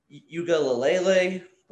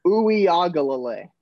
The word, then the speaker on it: Uiagalelei: First off, it's DJ Uiagalelei. It's Hawaii. Uiagalelei. Ooh, I can't say it. It's a tongue twister. Uiagalelei. There we go. That's how you say it.